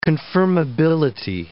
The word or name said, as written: confirmability